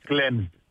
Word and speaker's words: Cleansed: Να πούμε λοιπόν για την παράσταση της, το έργο της Sarah Kane, το Cleansed, εάν το προφέρω σωστά, που παίζεται... – Ναι, λέγεται Cleansed.